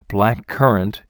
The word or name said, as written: black currant